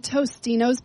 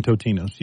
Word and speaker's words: Totino's: And then Tostino's pizza is now Totino's. – No, it's always been Totino's. You can't. That one I will not buy. It was always Totino's. People said it wrong.